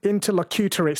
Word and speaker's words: interlocutory: It's pronounced interlocutory. Sorry, interlocutory. That is correct, yes.